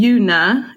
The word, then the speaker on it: Yuna: I've let it slide because my parents, it was going to be Yuna. It was a given name. And then my parents changed the U to like a short U sound. Yun, like fun or run.